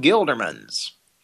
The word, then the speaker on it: Gildermans: Sean Gildermans. At nowhere in particular. – Can you please spell it? – S-E-A-N. G-I-L-D-E-R-M-A-N-S. – G-I-L-D-E-R-M-A-N-S.